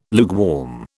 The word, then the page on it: lukewarm: British English